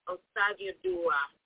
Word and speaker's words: Osagioduwa: Okay, it's pronounced Osagioduwa.